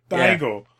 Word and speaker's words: Daigo: It's like Diego, except Japanese. Daigo.